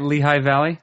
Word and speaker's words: Lehigh Valley: Is that how you say it? Lehigh Valley? – Yep.